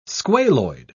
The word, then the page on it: squaloid: British English